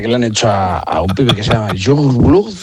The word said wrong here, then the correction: Youngblood: Es que era muy difícil de pronunciar, pero luego ¿cómo se pronuncia? – Youngblood.